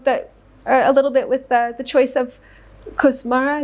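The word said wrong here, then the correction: Košmara: is that how you pronounce it? – Koshmara. – Koshmara, mm-hmm.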